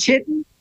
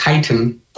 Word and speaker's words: chitin: So a friend of mine, and I'm sure It's not chitin. – It looks like chitin, and it's actually pronounced chitin.